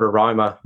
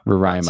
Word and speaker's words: Roraima: Yeah, so it's Mount Roraima. – Roraima. – So it's pronounced, yeah.